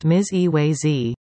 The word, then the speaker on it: Mizawazi: Mizawazi, pronounced Miz-E-Way-Z, means, he is whole, in Ojibwe.